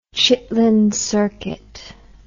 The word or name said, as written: chitlin circuit